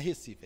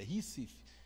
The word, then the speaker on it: Recife: Não é Recife, é Recife.